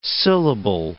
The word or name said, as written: syllable